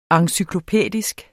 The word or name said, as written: encyklopædisk